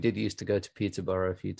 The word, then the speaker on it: Peterborough: In the, we pronounce it Peterborough.